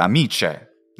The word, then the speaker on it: amicae: Ma in latino si scrive amica singolare, amicae, ma si pronuncia amice nella pronuncia ecclesiastica.